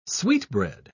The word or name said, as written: sweetbread